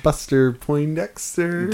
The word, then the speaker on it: Buster Poindexter: A real Buxter Bundesser. – What? – And one more again. – I'm the one who mispronounces things. – A real Buster Poindexter. – There we go.